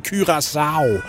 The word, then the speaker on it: Curaçao: Det er sådan, Andreas Kravl udtaler det, og om nogen må gøre det rigtigt, så må det være ham. Jeg har hørt ham sige Curaçao i fodboldlisten, så det må være rigtigt.